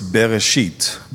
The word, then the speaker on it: Bereshit: So in Hebrew, it's Bereshit. Be careful how you say it.